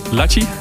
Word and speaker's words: Lachi: Is that the way to pronounce it? Lachi? – Lachi.